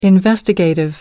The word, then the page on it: investigative: American English